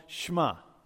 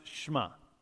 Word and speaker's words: shema: It's called shema. It's pronounced shema. We can say it together. Shema. It's not a shema. You know, that could be West Texas way to shema.